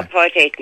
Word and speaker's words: apartheid: Now, apartheid means the same as your segregation. It does not mean apart and hate, as it is pronounced by most people.